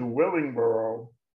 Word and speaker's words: Willingboro: Am I saying that right? Willingboro, is that how you say it? – Yeah, correct. You're right.